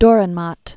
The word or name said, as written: Durrenmatt, Friedrich